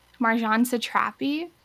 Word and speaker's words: Marjane Satrapi: Marjane Satrapi.